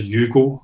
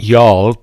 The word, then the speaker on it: Youghal: Don't know if I'm pronouncing that correctly. – That would be Yarl, but good try. – I'm just looking at it, reading it as Youghal, but Yarl. That sounds like you're from the south of America. You know, you say, hi, y'all.